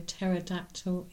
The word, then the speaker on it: pterodactyl: Why can't you hear a pterodactyl in the toilet? I don't know. Go on. – Because it has a silent P.